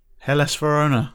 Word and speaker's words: Hellas Verona: Oh, Joe, Joe, Hellas Verona. No, bollocks. – you were so cocky. at least you, at least you said it right.